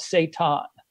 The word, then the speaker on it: seitan: actually called it's, it's spelled S E I T A N with a thingy over the A. So, so they call it Satan.